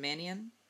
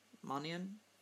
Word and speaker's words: Mannion: It's Sean Mannion. Mannion? M-A-N-N-I-O-N.